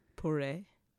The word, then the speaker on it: Poiret: how would you say this? It's Pourette, P-O-I-R-E-T. – Pourette. – Pourette, king of fashion. – Pourret.